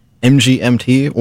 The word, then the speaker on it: MGMT: Before that, you heard MGMT, or Management, if you want to pronounce it like that.